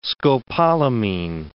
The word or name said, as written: scopolamine